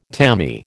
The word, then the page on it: tammy: American English